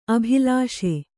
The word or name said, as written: ಅಬಿಲಾಸೆ